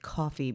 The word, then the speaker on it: Ko-fi: I still refuse to pronounce it coffee because it looks like ko-fi should rhyme with lo-fi, doesn't it?